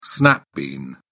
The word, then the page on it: snap bean: British English